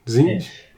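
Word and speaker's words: Tzeentch: How do you pronounce it? Zinch. – Zeech.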